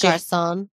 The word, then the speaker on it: Garçon: From Comme des Gargons, Garçon. I know I butchered that. That's French. That's French. French. – Garçon, like as in boy, like garçon. – G-A-R-C-O-N-S.